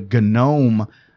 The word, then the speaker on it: GNOME: And I didn't know that there was all this controversy at the time about Unity because they had moved away from the GNOME desktop, which I'm not mispronouncing. And there's a reason that the G is pronounced.